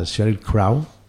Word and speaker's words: Sheryl Crow: Bueno, ahora hablando en serio, si no los conocéis, Wildflower empezaron como un tributo a Sheryl Crow, ¿díjelo bien? ¿Pronuncias así? – Sí, sí.